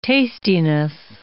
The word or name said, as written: tastiness